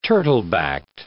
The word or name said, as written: turtle-backed